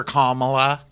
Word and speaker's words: Kamala: And then they're asking what Kamala can we just have a moment for Kamala? – Okay, I said it wrong. – Let's take a moment. – Kamala. I hate being that person who always says her name wrong, but I am, and it's like the worst thing in the world.